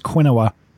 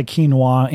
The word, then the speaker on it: quinoa: It looks like it should be pronounced quinoa. It's yeah. Q-U-I something or after that. Just say quinoa and somebody with purple hair will show you where it is on the shelf.